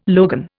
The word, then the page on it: logan: Indian